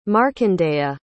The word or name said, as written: markandeya